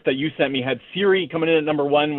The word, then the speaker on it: Ciri: Is it Siri? Is that how it's pronounced? Because you're talking to a video game idiot here. I know nothing. Is that how you say the name? C-I-R-I?